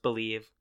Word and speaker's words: Bleav: Yeah, so Believe in Timberwolves, spelled B-L-E-A-V, but it's pronounced Believe.